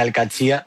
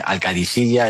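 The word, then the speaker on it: Al Qadsiah: Bueno, se suele decir Al-Katsi, pero también hay gente que dice Al-Kadisiya, es que es difícil, ¿eh? Ah. No es fácil, pero Al-Katsi es lo más fácil.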